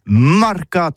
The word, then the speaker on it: marcats: Mais en gascogne toulousain, c'est prône sur les marcats, avec un A marcat. En gascogne toulousaine, du côté, par exemple, de l'île des Bâches, c'est l'île Jourdain. Pour parler du marché, vous prononcez les marcats.